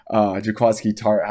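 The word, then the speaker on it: Jaquiski Tartt: against the San Francisco 49ers and Jaquez Key. Trying to say his name once again, it's not going well. Jaquaski Tart had that dropped interception right in the middle of the field.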